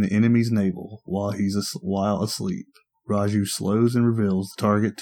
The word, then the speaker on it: Raiju: Second ability, Raiju. Am I saying that right? – Yep. – Raiju? – Yep, Raiju.